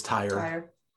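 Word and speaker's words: TYR: Is it tire? Is that how you say it? – Yeah, that's how I've always known it is tire.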